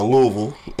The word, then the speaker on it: Louisville: Uh, but this morning, so uh, we headed out of town, getting ready to go to Louisville uh, or Louisville, rather. I'm sorry, gotta say it correctly for people from Kentucky.